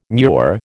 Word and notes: murex: American English

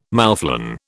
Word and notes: moufflon: British English

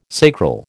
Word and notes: sacral: American English

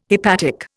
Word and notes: hepatic: Indian

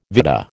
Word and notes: vitta: American English

